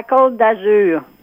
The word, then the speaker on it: Côte d'Azur: It's pronounced La Côte d'Azur.